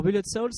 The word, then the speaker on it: Bullet Soul: Euh, on, va, on va commencer par Bullet Soul. Je le dis bien, hein J'ai un bon accent, hein ouais. très très bien. – Voilà, alors Bullet Soul, c'est.